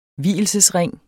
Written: vielsesring

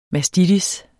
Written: mastitis

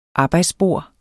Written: arbejdsbord

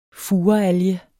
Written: furealge

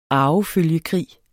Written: arvefølgekrig

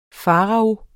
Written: farao

